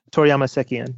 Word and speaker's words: Toriyama Sekien: Toriyama Sekien, yes.